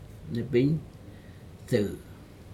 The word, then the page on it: nepig dou: Aneityum